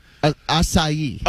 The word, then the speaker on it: acai: I was calling it a Asia. – Acai. – Oh, yeah, okay. – Everybody in L.A. knows how to pronounce acai. – Yeah, in Cleveland, not so much. – A-C-A-I.